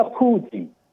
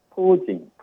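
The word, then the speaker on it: 铺 景 (Путин): 它叫它叫铺景，怎么？ – 那个重音在前面。 – 再再念一次。 – 铺铺景，铺景，铺景。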